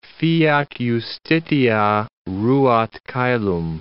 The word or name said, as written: fiat justitia, ruat caelum